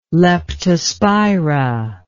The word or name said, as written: leptospira